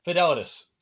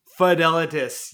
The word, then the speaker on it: Fidelitas: I'm going to have to get you to pronounce the name here. – Fidelitas. – Fidelitas. Yes, perfect.